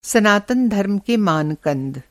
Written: मान-कंद

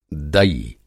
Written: दई

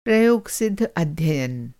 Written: प्रयोगसिद्ध

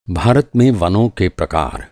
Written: प्रकार

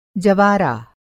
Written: जवारा

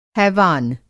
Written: हैवान

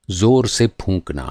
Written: फूँकना

